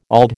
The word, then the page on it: auld: American English